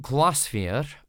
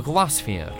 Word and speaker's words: Glaisvair: The album's called Glassphere, or at least that's how I've been pronouncing it. It's spelt G-L-A-I-S-V-A-I-R,